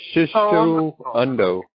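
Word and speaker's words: Shiso Undo: It's Shishu Undo. They send us their paper. It's spelled S-H-I-S-O. The Undo is U-N-D-O.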